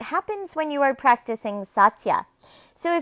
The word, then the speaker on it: satya: The second yama in Sanskrit is pronounced satya, and in English it means truthfulness.